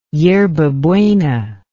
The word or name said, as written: yerba buena